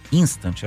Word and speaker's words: Instant: Essa nova funcionalidade do Instagram, esse Instant, assim mesmo se pronuncia, pessoal.